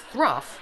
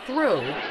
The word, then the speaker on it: through: So then she keeps going on, and he goes, "Then his day's work was thruff." She's like, "No, it's through." – When the... – Hold it, Shakespeare. – Through? – T-H-R-O-U-G-H is pronounced through.